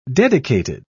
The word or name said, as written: dedicated